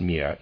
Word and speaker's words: mir: Take, for instance, the word mir, which is mine, pronounced mir in High German, and look at how it is pronounced in some Central European dialects. When I was a little boy, I couldn't imagine that the word was spelled M-I-R.